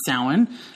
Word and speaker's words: Samhain: And that comes from a holiday called Samhain, which looks like it's Samhain is what it looks like, right? But it's pronounced Samhain.